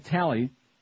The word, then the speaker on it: Talley: The film's remarkable effectiveness is also due to the fact that Silence screenwriter Ted Talley, rhymes with Pally,